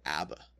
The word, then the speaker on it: ABBA: I think they're called ABBA. – Yeah, you're right. I said it wrong. – You said it like the Hebrew.